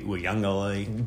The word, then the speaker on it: Uiagalelei: They've got 7 starters back on offense, plus they're bringing in former Clemson quarterback DJ Uiagalelei. – Good pronunciation there.